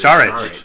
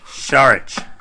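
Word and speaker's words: Šarić: Also, make sure you're pronouncing it's Saric. – Saric. – Come on, Mark Zumoff doesn't break his lisp every single game pronouncing it that way for nothing.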